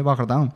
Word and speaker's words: Bardin: que en realidad Bardín no se llama Bardín, como os he dicho, es francés, ¿no? Y se llama René Bardin.